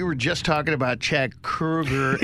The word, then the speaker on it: Kroeger: The problem is people were like, hey, I got Chad Kroeger in. So, Chad, tell us. And he never had time to correct people that his name is not pronounced Chad Kroeger. It's pronounced Chad Kroeger, like Freddy Krueger.